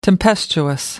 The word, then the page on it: tempestuous: American English